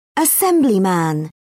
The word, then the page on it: assemblyman: British English